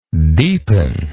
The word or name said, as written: deepeningly